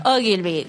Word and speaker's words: Ogilvy: Ogilvy.